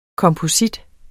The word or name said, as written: komposit